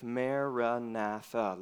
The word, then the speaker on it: Maranatha: We recommend the prayer phrase, Maranatha. Recite it as four syllables of equal length, Maranatha.